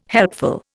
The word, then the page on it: helpful: Indian